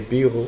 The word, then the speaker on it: bureau: And for example, when you say bureau, for yeah. example, you add like three letters. So you say the O in French.